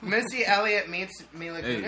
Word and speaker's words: Mila Kunis: Mila Kunis. – Let's have Missy Elliott, too. – Did I say that right? – Yeah, it's Mila, Mila, Mila, Mila.